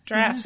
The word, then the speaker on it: draughts: Drafts. Yeah. It looks like draughts. It's pronounced drafts.